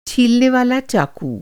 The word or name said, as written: छीलने वाला चाकू